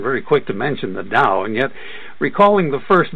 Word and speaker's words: Tao: The sense of Tao, the T-A-O, sometimes D-A-O, it's pronounced as a D in any case,